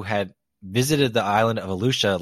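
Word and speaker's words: Eleuthera: I think it's pronounced Alusha.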